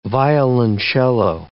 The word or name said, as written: violoncello